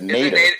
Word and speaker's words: nadir: I went to look up nadir, which that's not how you say it. Google told me. They said nader. – Is it nader? – It is nader.